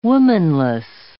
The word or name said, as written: womanless